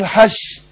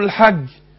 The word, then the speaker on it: الحج: فعشان كده اللي بيجي ينطق يقول لك الرشيم، هنروح المسجد مش، يروح ناطق الايه الجيم شين برضه، يقول لك الحش الحش، عايز يقول الحج الحج فيقول حش، يبقى ينطق الجيم ايه شين.